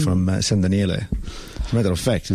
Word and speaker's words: San Daniele: San Daniele.